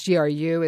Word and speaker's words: GRU: and also to place sanctions on members of the GRU. Is that the correct way to say that? – That's right.